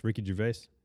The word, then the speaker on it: Ricky Gervais: Ricky Gervais.